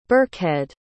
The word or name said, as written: birkhead